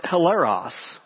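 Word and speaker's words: hilaros (ἱλαρός): When Paul uses the word cheerful in verse 7 to describe the kind of giver that God loves, the Greek word that he uses there is pronounced hilaros.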